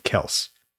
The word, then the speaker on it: Kelce: And they recently found out, or recently said, that their last name, Kelce, is actually pronounced Kelse. – Oh, because is it spelled K-E-L-S-E? – Close. C, not an S, but yes.